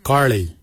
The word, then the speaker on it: Giilyi: Darb is spelled D-H-A-A-B. It is a traditional knife, a darb. For boomerang, we say karli. Karli is the traditional name for boomerang. Karli is spelled G-I-I-L-Y-I, karli.